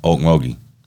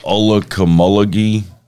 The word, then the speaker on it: Okmulgee: We've got a river called the Okmulgee, and to me it's easy to say because I've said it my whole life, Okmulgee. But the way it's spelled, you should hear people trying to pronounce it that aren't from here, like Olukamulgee, Olukamukugli. There's way too many letters in it, but everything here's named after trees and stuff.